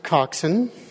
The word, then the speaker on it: coxswain: It's spelled coxswain. It's pronounced coxswain.